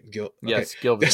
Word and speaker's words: Gilvezan: I said it right that time, right? – Gilvezan.